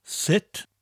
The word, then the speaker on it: sut: In South Wales, we just pronounce the word sut differently. We pronounce it as shwd.